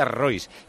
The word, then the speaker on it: Reus: Que el pronunciador del Mundial dice que se pronuncia Royce.